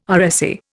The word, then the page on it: RSA: Indian